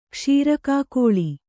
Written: ಕ್ಷೀರಕಾಕೋಳಿ

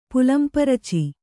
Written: ಪುಲ್ಲಂಪುರುಚಿ

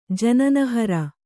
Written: ಜನನಹರ

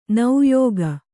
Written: ನೆಯಿಗೆ

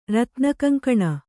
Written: ರತ್ನಕಂಕಣ

